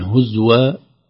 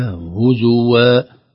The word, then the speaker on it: هزوا: هزوى، انتبه لنا، نقول هزوى يعني هكذا بسكون الزاي، القراءة الصحيحة هزوى، مثل كلمة كفوا، كفوا أحد، أيضا هنا هزوا وغرتكم.